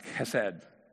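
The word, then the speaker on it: chesed (חסד): It's usually written H-E-S-E-D. But it's pronounced chesed, you know, it's loyal love.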